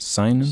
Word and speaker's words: seinen: Am I saying that right? Seinen? – Seinen, yeah. – Manga